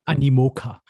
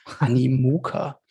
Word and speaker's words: Animoca: Ich hoffe, ich habe es jetzt richtig ausgesprochen. – Animoca. Animoca.